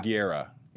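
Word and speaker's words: Gurira: Yeah, I mean, how do you say her last Guerra? – Guerra. Yeah.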